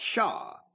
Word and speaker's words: Hsia: President Clinton, back a few years ago, had a cabinet member named Shaw. It was pronounced Shaw, but in Chinese, it was spelled in English, H-S-I-A.